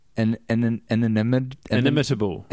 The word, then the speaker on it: inimitable: Inimitable. Inimitable. – Say imitable. – Imitable. – Inimitable. – Inimitable. Is that right? – No, inimitable. – You're putting the emphasis on the wrong syllable.